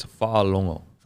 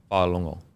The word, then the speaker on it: Fa'alogo: I think it's Fa'alongo. – Fa'alongo. – So I don't think you say the G-go. Fa'alongo.